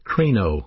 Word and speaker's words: krino: The Greek New Testament word for judge is a verb pronounced krino, K-R-I-N-O, krino.